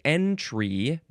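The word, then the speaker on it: entrée: E-N-T-R-E-E. It looks like entree, but it is pronounced entree. Looks like you can you can emphasize either syllable. Entree, entree.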